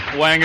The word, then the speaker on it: Wang: W A N G is uh, Northern and Central Chinese. W O N G is Southern Chinese.